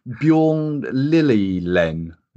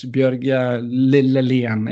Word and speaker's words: Bjørge Lillelien: Is it Bjørn Lillelien? – In Norwegian, it's Bjørge Lillelien.